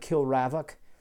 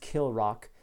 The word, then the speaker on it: Kilravock: And it looks like when you spell, when you read it, it looks like Kilravock, but my understanding is it's pronounced Kilrock.